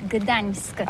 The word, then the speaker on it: Gdańsk: Oui, c'est pas très facile pour des étrangers, mais remarque bien que la langue polonaise, c'est la langue où on prononce chaque lettre qu'on écrit. C'est pourquoi on dit Gdańsk.